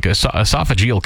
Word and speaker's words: esophageal: we're at a higher risk of getting esophageal cancer. Is that how you say it? – Yeah. – Unfortunately, the survival rate for like esophageal cancer is a horrible one to get.